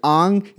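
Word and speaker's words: Aang: Don't even pronounce the names correctly. Ang, it's Ang, you motherfucker. Fuck.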